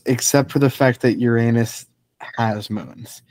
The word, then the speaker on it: Uranus: Uranus, I guess, technically, is how it's pronounced.